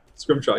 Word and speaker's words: scrimshaw: but I do have am I pronouncing this right? Scrimshaw sculptures." – Scrimshaw, yep.